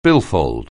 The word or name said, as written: billfold